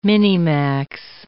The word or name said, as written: minimax